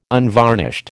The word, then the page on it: unvarnished: American English